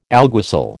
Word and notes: alguacil: American English

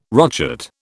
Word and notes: rochet: British English

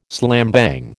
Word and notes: slambang: American English

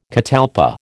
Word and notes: catalpa: American English

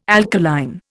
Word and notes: alkaline: Indian

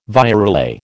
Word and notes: virelay: American English

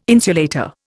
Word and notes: insulator: Indian

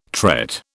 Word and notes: tret: British English

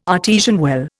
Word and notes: artesian well: Indian

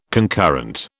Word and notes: concurrent: British English

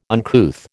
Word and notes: uncouth: American English

